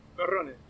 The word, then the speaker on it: Perrone: Do you pronounce the E at the end of your name? – Perrone. – Yeah. Perrone, that's what I thought.